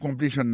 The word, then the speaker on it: completion: Pour completion nan li menm, accent tombé sous deuxième syllabe là.